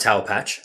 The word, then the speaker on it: Taopatch: So it's called Taopatch. That's correct pronunciation of it, I, I presume. Yep.